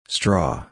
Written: straw

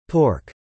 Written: pork

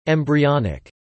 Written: embryonic

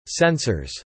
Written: sensors

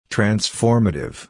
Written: transformative

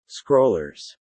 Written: scrollers

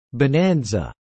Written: bonanza

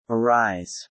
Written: arise

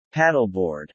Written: paddleboard

